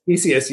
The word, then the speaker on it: Theseus: Is it Ship of Theseus? – Theseus, yeah. – Theseus, that's what I thought.